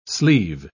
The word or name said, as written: sleeve